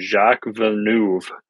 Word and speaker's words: Jacques Villeneuve: But my FanDuel 50-50 cash game hit with Cindric, Kyle Busch, and actually Jacques Villeneuve.